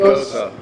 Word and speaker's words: Gose: Gose. – Gosa. – Gosa. – So it's like Rosa with a G.